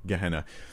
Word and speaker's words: Gehenna: We pronounce it, you know, Gehenna.